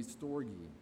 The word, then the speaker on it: storge: It's S-T-O-R-G-E, pronounced, I believe, storge.